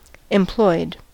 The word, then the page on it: employed: American English